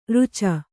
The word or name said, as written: ಋಚ